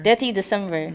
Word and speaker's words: Detty December: I'm going to go to Detty, how you say it? Like, Detty December. – Detty December.